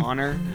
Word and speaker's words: Zauner: Honestly, she had a solo project, Michelle Zauner. I don't know if that's how you pronounce her name. Is that her name? – Zauner.